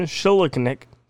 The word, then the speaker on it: Schildknecht: The monster that was subject of the 1988 film by Louisville filmmaker Ron Shilichnik, and I know I didn't pronounce that right, so I'll spell it, S-C-H-I-L-D-K-N-E-C-H-T,